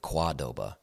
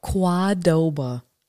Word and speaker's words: Qdoba: She's like, you actually pronounce it Qdoba. – Qdoba.